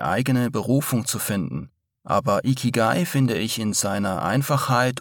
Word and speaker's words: Ikigai: Ikigai wird so ausgesprochen, wie es geschrieben wird.